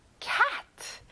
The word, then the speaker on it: cat: 고양이, cat. 친구들, 에 발음이라서 입을 크게 벌려보는 거예요.